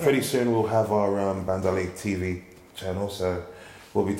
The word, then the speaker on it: Bendalé: Bendalé.